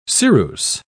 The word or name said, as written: ceruse